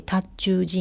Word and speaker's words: 塔頭: 塔頭というのはなかなか分かりにくいんですけど、漢字書くと塔という字に頭と書いて塔頭寺院っていうふうに言うんですが、